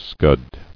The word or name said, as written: Scudded